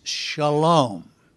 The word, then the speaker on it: Shalom: Now, in the Hebrew, it is Shalom. Anytime you hear the word Salem, it is Shalom. That's the Hebrew, how it's said in Hebrew.